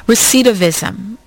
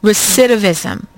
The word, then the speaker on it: recidivism: But I want to talk about, since we're getting so close to the mid-hour, recidivism. Is that the correct pronunciation? Recidivism.